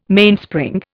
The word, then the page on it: Mainspring: Indian